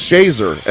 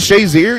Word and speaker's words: Shazier: Initially they said it's pronounced Shazer. And I was like, I asked his dad. I'm like, isn't it Shazier? And he said, yes.